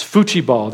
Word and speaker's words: futebol: It's futebol there. That's how you pronounce it in Portuguese.